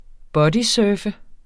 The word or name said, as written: bodysurfe